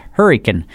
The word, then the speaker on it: Hurricane: Country lawyer in Hurricane, West Virginia, which we pronounced Hurricane.